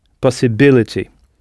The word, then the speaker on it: possibility: En frappant la syllabe « b », possibility. Alors, au pluriel, « possibility » donnera « possibilities », avec « i-e-s » à la fin, prononcé « is », tout comme le mot « cities » que nous avons vu précédemment.